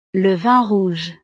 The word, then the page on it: le vin rouge: frances